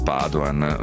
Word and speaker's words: Padoan: Dall'Europa è arrivato comunque un plauso ben augurante nei confronti del neo ministro dell'economia Piercarlo Padoan, o Padoan, insomma, dipende se vogliamo pronunciarlo alla veneta o no, perché lo ha detto lo stesso commissario agli affari economici Olli Rehn.